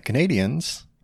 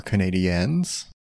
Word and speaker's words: Canadiens: But they're playing the Canadiens, or Canadiens, depending on how you prefer to pronounce it.